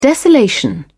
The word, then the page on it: desolation: British English